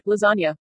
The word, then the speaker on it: lasagna: like how the letter G is silent in the pronunciation of the word lasagna.